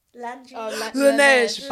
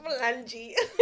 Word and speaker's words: Laneige: Laneige. Buy it. – Laneige.